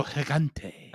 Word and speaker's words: Gigante: El Gusano Gigante. – Gigante. Yeah. – You hear that roll behind it.